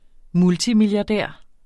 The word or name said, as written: multimilliardær